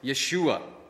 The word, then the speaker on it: Yeshua: In fact, the Hebrews pronounce Joshua as Yeshua.